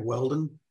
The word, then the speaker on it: Whelden: just type in my name, Craig Whelden, W-H-E-L-D-E-N, not O-N.